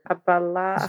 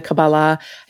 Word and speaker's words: Kabbalah: I know that in the Kabbalah, I think I'm saying that right, they call your... – Kabbalah. – Thank you, the Kabbalah.